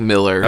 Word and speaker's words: Millar: We, someone, someone looks like Millar, pronounced like Miller.